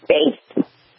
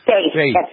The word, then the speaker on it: Faith: I think he got the name wrong. It's Faith. Faith. F-A-I-T-H.